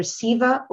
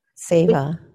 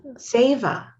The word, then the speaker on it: seva: It's either Siva or Seva. – Seva. – Seva. – Yeah, keep doing seva, which is selfless service.